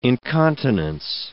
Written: incontinence

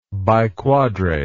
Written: biquadrate

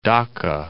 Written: Dhaka

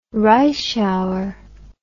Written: Reischauer

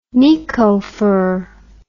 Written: nikko fir

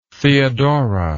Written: theodora

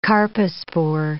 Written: carpospore

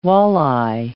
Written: walleye

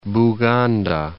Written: Buganda

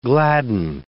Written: gladden

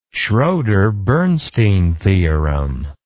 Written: schroder bernstein theorem

